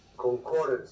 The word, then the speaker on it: concordance: Concordance or concordance? Concordance. – Concordance, second syllable.